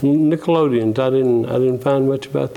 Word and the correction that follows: Nicolaitans: Is it Nickelodeon? Is that the way you pronounce it? – The churches? – It's in the sixth verse. – The Nicolaitans. – Oh, well, that's why I couldn't do it. I was calling it wrong, I guess.